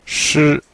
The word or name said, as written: shi1